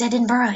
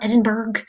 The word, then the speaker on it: Edinburgh: And oh, that's how you pronounce Edinburgh. It's not Edinburgh.